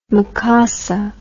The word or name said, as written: makossa